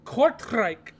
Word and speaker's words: Kortrijk: Ooh, it's pronounced Kortrijk. – Kortrijk. – Whatever. – You only can say that angry, dude. Why do they pick words that you only can say angry? – It's Dutch. – Oh.